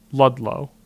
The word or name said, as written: Ludlow